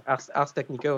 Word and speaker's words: Ars Technica: Ars Technica. Ah, si j'ai besoin de prononcer ça. – Ars Technica, oui.